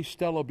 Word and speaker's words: stele: I say stela because that's how they say it. They say it in Spanish, estela. It's hard for me to say, and I'm so used to reading Maya history, but I'll try to say stele.